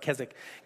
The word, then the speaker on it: Keswick: You ever see it spelled out? It's Keswick, but it's pronounced Keswick.